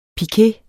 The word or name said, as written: pike